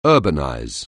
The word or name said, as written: urbanize